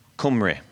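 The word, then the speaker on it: Cymru: Cumre.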